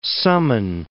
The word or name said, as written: summon